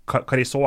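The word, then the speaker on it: Carriço: Eh, Dani Carijo har, eller Carisot er det vel når det uttales, eh, har flest kamper i Europaligas historie.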